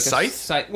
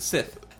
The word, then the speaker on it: scythe: S-C-Y-T-H-E, right? Sith? – Like Scythe? – Well, yeah, some people pronounce it Sith. I've heard it pronounced Sith.